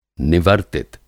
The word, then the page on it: निवर्तित: Hindi